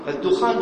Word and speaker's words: الدخان: كثير من ينطق الدخان يشدد يقرأ شجرة الدخان الدخان بالتخفيف في القرآن وفي غيره